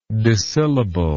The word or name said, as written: dissyllabic